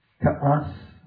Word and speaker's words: Kaas: So I want to go back to 3708, Kaas, K-A-A-S, emphasis on the first two letters.